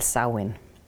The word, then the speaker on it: Samhain: Den staves jeg nemlig Samhain, men udtalt Samhain.